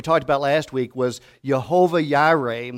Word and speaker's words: Jehovah Jireh: Now, that's in the Hebrew, but in the Hebrew language, they don't pronounce the letter J as we do. And so if we looked at that in our language, it would be Jehovah Jireh, and it means the Lord will provide.